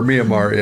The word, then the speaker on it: Myanmar: I keep saying I've said it wrong forever. Myanmar, yes.